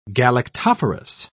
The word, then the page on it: galactophorous: British English